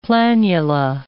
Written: planula

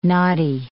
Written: knotty

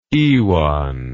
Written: iwan

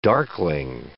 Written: darkling

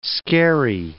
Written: scary

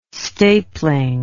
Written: stapling

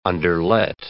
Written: underlet

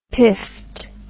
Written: piffed

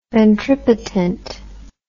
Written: ventripotent

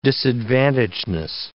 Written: disadvantagedness

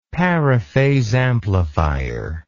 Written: paraphase amplifier

